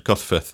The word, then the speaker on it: Gosforth: But in most people call Gosforth, it's more of a, you could write it as F-U-T-H. That's how it's pronounced at the end, Gosforth.